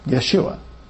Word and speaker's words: Yeshua: Yeshua. Yeshua. If it's pronounced Yeshua, you just simply know that that's where that German influence is in our translations. They, instead of a Y, they put a J there.